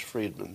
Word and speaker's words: Fridman: I'm seeing it's pronounced Fridman, thank you.